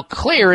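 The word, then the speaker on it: Xlear: Now, Clear is spelled with an X, X-L-E-A-R, Clear Max Nasal Spray.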